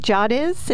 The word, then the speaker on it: Dziadosz: Dziadosz? Is that how I pronounce yes. – it? Okay, great. – Yep, you got it. – All right. Jackie Dziadosz is the marketing coordinator, and you are at the Union County Library System.